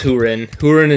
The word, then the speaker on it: Húrin: The Children of Húrin, which I think is how you're supposed to pronounce it. – It is Húrin and Túrin.